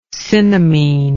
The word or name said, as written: cinnamene